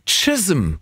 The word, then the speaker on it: Chisholm: Melanie Jane Chisholm. So wird es ausgesprochen. Das ist aber wirklich ein C, was da geschrieben steht und wird mit T ausgesprochen.